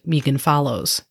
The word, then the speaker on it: Megan Follows: 3,000 girls auditioned for the main character role of Anne Shirley, including a talented Canadian actress named Megan Follows. And that pronunciation is correct. It is Megan, not Megan.